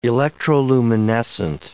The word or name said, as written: electroluminescent